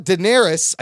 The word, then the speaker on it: Daenerys: Let's talk about Daenerys. I said it right.